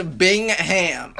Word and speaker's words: Bingham: They return Cassius Winston, Matt McGway, Joshua Langford, and Nick Ward, and they also bring in highly rated recruit Marcus Bingham. – That's one pronunciation of it. – It's Bingham. Okay.